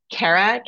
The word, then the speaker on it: Kara: Kara, KARA.